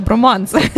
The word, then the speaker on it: Bromance: Насправді, тобто, те, що Максим наголошує на те, щоб це був броманс, щоб не було наголосу на романс.